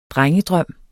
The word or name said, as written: drengedrøm